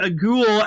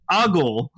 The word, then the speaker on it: Agul: I kept fucking throwing X8 in his face and like apparently, Ichi accidentally pronounced Ultraman Agul as Ultraman Ogle or Eagle. – Eagle. – How the fuck was I supposed to know? – There's a U in there.